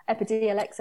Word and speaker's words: Epidiolex: Yeah, the Epidiolex, or how did you pronounce it? – Yeah, Epidiolex. – Okay, yeah.